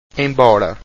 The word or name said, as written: embora